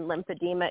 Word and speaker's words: lymphedema: My father had very, very significant edema and swelling and lymphedema issues.